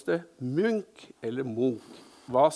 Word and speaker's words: Munch: Så de insisterte på at det skulle uttales Munch.